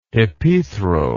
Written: hypethral

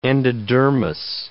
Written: endodermis

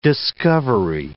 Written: discovery

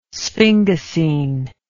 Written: sphingosine

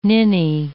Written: ninny

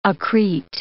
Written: accrete

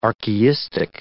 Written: archaistic

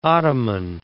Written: ottoman

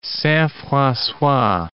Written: Saint-Francois

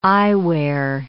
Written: eyewear

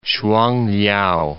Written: Shuangliao

